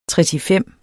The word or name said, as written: tretifem